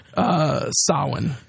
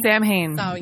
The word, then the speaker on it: Samhain: So I was pronouncing it Samhain as well because there's a character in Hackslash called Samhain, but there's a pronunciation key in here that says Samhain.